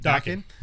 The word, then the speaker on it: Daken: And then we have Dark Wolverine, who's Daken. Did I say it right? – Daken.